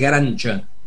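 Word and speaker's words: Garanča: Bueno, Leo, vamos a ir ahora al tema también espiritual del día de hoy, que es un recital de la mezzosoprano Elina Garanca, en realidad es Garancha la pronunciación, acompañado por una orquesta que dirige su marido, Karel Mark Chichón.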